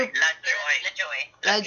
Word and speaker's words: LaJoie: Like super LaJoie.